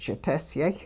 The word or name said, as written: ch'itesyay